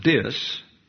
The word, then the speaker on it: diss: Are we having people make fools of themselves based on a lie to disrespect, if I may use the term, I understand that it's properly pronounced dis, to diss the national anthem by sitting down while it's being played at a sporting event.